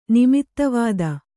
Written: ನಿಮಿತ್ತವಿದ

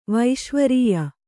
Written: ವೈಶ್ವರೀಯ

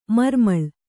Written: ಮರಮಳೆ

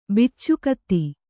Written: ಬಿಚ್ಚುಗತ್ತಿ

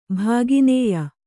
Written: ಭಾಗಿನೇಯ

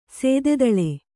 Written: ಶತದಳ

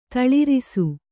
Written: ತಳರಿಸು

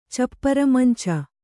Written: ಚಪ್ಪರಮಂಚ

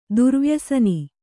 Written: ದುರ್ವ್ಯಸನಿ

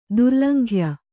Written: ದುರ್ಲಂಘ್ಯ

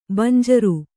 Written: ಬಂಜರು

